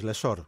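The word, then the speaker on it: Lessort: Αν και, να σου λοιπόν, πω κάτι, στα γαλλικά δεν ξέρω αν τα δύο S. – Όχι, το προφέρει ο ίδιος, Λεσόρ. – Άρα είναι Λεσόρ.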